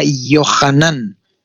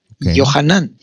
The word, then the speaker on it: Yohanan: Tu nombre se escribiría con Y, que es un Yod, y se pronunciaría Yohanan. Yohanan.